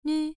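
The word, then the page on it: nv: tone 1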